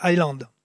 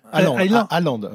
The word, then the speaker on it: Allende: Allende. – Ah euh, ah, Allende. Bah écoutez, je voulais le prononcer à l'anglais. On va le faire à la française.